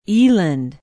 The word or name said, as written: eland